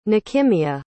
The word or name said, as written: nechemya